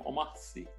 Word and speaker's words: Omar Sy: Como é que fala? Omar Sy? Omar Sy? Ele é francês, né? Então, Omar Sy.